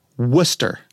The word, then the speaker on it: Worcester: But in Massachusetts, they pronounce Worcester, Worcester. – Right. It's not Worcester.